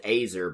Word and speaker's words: Azer: Which I'm gonna pronounce it Azer because it's like laser without the L.